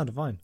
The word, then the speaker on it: Devine: It's all about this bank manager called Owen Browning, played by Adam Devine. Am I saying that right? – Yeah, sure. – Cool. I don't know if his name is Devine. – Devine, yeah. – Devine? Devine? – No, Devine. Yeah.